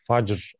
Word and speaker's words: Fajr: Bu arada o şey e, Arap transliterasyonunda, Arapça transliterasyonunda C diye okunuyor C'ler. Facr oluyor. Sanırım.